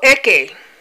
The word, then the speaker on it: Eke: So for Eke, you will have names. Eke is spelled E-K-E. That's correct. I'm doing Igbo pronunciation. E-K-E. Eke.